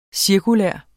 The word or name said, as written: cirkulære